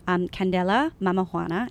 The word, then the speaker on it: Candela Mamajuana: So listeners, I'm holding up Candela, Mamajuana.